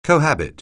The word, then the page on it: cohabit: anglais